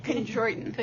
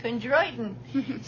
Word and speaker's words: chondroitin: how do you pronounce it? Glucosamine, what? Chondroitin. Chondroitin. so, and, but that does take, everyone says that takes about a month or so to work.